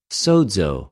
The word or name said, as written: σωζω